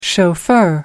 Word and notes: chauffeur: American English

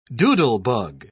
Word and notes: doodlebug: British English

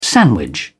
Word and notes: sandwich: British English

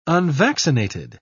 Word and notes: unvaccinated: British English

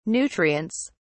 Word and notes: nutrients: British English

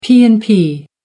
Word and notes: p and p: American English

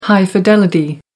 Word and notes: high fidelity: American English

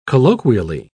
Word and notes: colloquially: British English

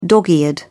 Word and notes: dog-eared: British English

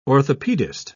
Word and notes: orthopedist: British English

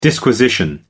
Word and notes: disquisition: American English

